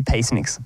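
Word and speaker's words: Peaceniks: How do you pronounce that word, Pete? Well, it's P Cynics or something. It's P E A C E N I K S. – Peacenicks? – It's probably, it would be Peacenicks, yep.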